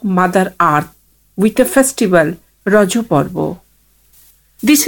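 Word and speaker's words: Raja: The festival is natively pronounced as Rajo, a local term that means menstruating woman.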